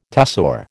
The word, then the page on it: tussore: American English